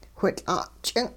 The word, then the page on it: whitL'a'-ch'ing': hupa